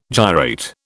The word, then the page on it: gyrate: British English